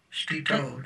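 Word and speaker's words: Stito (स्थितो): Ekam sena stetu jagat. – Stito jagat. – Stetu jagat. Thank you, Maharaj.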